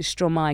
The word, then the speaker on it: Stromae: And one of my followers made a comment in the comment section and was like, it's Verlon, you pronounce it Stromae because it's maestro.